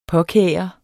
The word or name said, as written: påkære